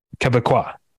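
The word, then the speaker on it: Québécois: No, because they don't say Quebec. It's Quebec. – Right. – Is how you say it. And so I think you would say Quebecois.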